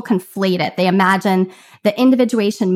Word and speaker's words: individuation: What kind of couple is individualization? I'm going to butcher that this whole time. Individuation. – There you go.